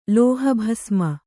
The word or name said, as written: ಲೋಹಭಸ್ಮ